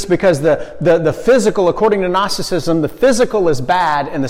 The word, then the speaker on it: Gnosticism: If you want to say it phonetically, Gnosticism, because there's a silent G at the front.